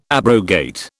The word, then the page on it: abrogate: British English